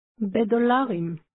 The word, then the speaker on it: בדולרים: What part of that means in? It's the b sound at the beginning of dollarim. – Say again, in dollars. – Bedolarim.